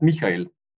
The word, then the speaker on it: Michael: Right my name is German, and it's pronounced Michael.